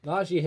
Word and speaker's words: Najee: So at 32, the new running back, Najee Harris. I knew I was pronouncing that wrong. Spanish. – The J's are actually J's.